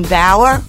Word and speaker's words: Bauer: And I'm so excited today because I have a special guest, Jean Bauer, rhymes with flower.